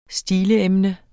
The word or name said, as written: stileemne